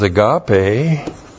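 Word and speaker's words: agape (ἀγάπη): The noun that comes from that is agape, A-G-A-P, long E, that's the noun love, but this is in the Epistle of John.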